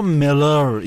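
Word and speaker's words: Müller: اصراری داشت که این مولر رو ملر اینجوری تلفظ میکنه و اون موقع اصر و اعصاب بود.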